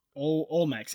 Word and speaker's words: Olmecs: Olmecs, yeah, that's how you say it.